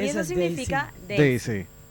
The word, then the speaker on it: Daisy: No, se lee, no. En inglés. – Daisy. – Daisy es la pronunciación de ese nombre.